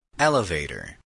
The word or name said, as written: elevator